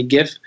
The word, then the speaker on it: GIF: At the company today, half the people actually say GIF and the other half say GIF. I'm more of the GIF clan because I think I like the soft G, not the hard G.